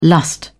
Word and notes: lust: British English

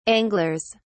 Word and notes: anglers: British English

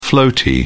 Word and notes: floaty: British English

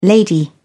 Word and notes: lady: British English

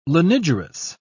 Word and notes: lanigerous: British English

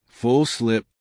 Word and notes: full slip: American English

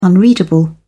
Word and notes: unreadable: British English

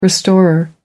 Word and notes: restorer: American English

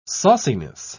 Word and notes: sauciness: British English

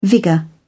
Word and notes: vigour: British English

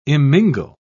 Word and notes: immingle: British English

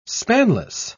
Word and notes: spanless: British English

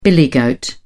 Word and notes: billy goat: British English

